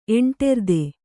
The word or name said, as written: ಎಂಟೆರ್ದೆ